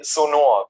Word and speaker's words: Sonor: We always say Sonor, because, you know, like the German way.